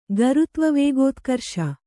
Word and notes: ಗರುತ್ವವೇಗೋತ್ಕರ್ಷ: Kannada

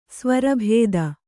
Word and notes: ಸ್ವರಬತ್ತು: svarabattu

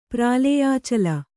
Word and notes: ಪ್ರಾಲೇಯಾಚಲ: prālēyācala

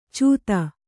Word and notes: ಚೂತ: cūta

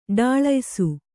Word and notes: ಢಾಳಯಿಸು: ḍhāḷayisu

